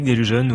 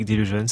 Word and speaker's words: Delusion: Ça se prononce comme ça, au fait. Depuis le début, je dis Isaac Delijeune, mais si ça se trouve, Isaac Delusion ou Isaac Delusion, ça dépend. – Et vous dites comment? – On dit les deux.